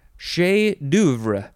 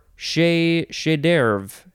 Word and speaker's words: chef d'oeuvre: Okay, next we have, could pronounce this a couple ways, chef d'oeuvre, or chef d'oeuvre, that sounds a little bit more American. Chef d'oeuvre seems more authentically French.